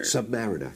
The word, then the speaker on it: Submariner: Or is it Submariner? – Submariner.